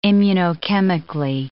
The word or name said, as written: immunochemically